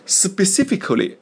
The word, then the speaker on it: specifically: Specifically, 这个单词注意了啊，注意发音啊，不是 specifically, 也不是 specifically, 而是 spe 那个 e 啊，发的是 e 短音 spe, 是那个短音 ii 的短音啊，